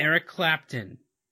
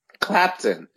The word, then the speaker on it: Clapton: Eric Clapton. – Clapton.